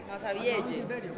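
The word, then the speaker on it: Massabielle: Masavielle. – Se escribe Masavielle. – Sí. – Y se lee Masavielle. Ahí está.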